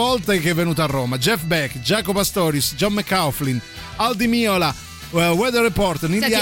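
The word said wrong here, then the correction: Al Di Meola: Allora ti prego però, Aldi Miola e John McLaughlin, ti prego mai. – Dimmi come si pronuncia, no davvero. – Aldi Meola, come dicono tutti, John McLaughlin.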